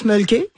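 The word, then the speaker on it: Mölkky: Alors, ça s'appelle le mulky. On prononce mulky. – Ouais. – Et c'est M-O-L-K-K-Y.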